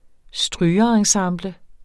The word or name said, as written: strygerensemble